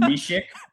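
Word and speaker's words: Mysák: Jan Mysak. Uh, his his his last name is pronounced Mishik.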